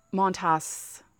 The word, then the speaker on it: Montas: But Montas, that's how you say his name.